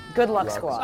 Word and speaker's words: Good Luck Squad: It rhymes with Duck Squad. Good Luck Squad.